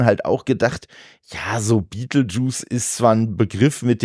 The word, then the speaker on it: Betelgeuse: Ja, die Sache ist die, Beetlejuice ist ja die englische Aussprache von dem Stern, den wir als Betelgeuse bezeichnen.